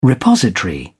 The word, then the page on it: repository: British English